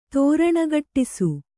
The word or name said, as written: ತೋರಣಗಟ್ಟಿಸು